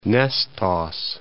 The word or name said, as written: Nestos